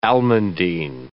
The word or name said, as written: almandine